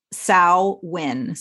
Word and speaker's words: Samhain: Like Leah said, it's not Samhain, even though I guess phonetically that might be how you'd say it. So the word Samhain is Gaelic in origin, and it's pronounced Samhain. So like S-O-W-W-I-N.